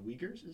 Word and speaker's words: Uyghurs: And he was actually very unhappy about their actions because among them, I didn't know how to pronounce it, but the Uyghurs is how you say it, is that they are heavily persecuted.